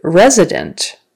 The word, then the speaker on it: resident: So let's go on to resident. Resident. Now it's on the first syllable.